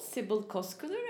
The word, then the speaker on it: Sibel Coskuner: I am used to be called Sibel Coskuner, and I'm fine with that.